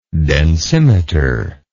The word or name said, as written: densimeter